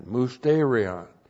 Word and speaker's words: mysterion: M-U-S-T, long E-R-I-O-N, mysterion.